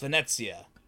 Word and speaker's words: Venezia: Yeah, it's Venezia.